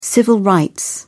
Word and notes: civil rights: British English

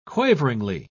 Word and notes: quaveringly: British English

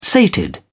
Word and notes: sated: British English

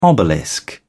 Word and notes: obelisk: British English

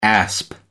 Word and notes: asp: American English

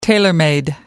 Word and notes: tailor made: American English

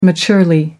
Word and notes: maturely: American English

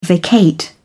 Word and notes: vacate: British English

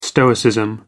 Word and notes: stoicism: American English

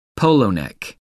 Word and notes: polo-neck: American English

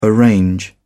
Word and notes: arrange: British English

